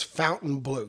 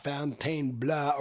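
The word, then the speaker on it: Fontainebleau: The official pronunciation is Fountain Blue. Good old American Fountain Blue, not Fontaine Bleu or whatever we, you know.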